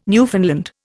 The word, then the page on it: Newfoundland: Indian